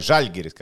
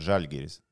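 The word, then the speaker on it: Žalgiris: Bet viņi bija pārāk pārāk žēlgiris vinnēji. Nē, žaļgiris, kā teica Lašausks. Pareizi jāizrunā, so žaļgiris.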